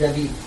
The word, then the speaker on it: David: It's spelled like David, but it's pronounced David.